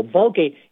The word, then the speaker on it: Vulgate: Vulgate, it sounds like the word vulgar, and you'd be right,